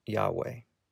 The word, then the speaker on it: Yahweh: It is four consonants transliterated from Hebrew as YHWH, generally pronounced Yahweh.